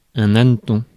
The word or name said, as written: hanneton